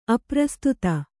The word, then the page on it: ಅಪ್ರಸ್ತುತ: aprastuta; Kannada